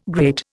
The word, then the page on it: great: Indian